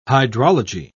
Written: hydrology

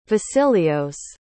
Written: vasileios

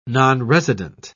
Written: nonresident

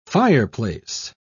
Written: fireplace

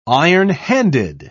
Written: ironhanded

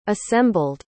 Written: assembled

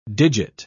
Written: digit

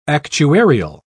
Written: actuarial

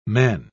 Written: men

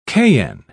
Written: cayenne

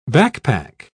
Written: backpack

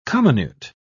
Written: comminute

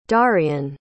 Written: darian

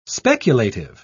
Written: speculative

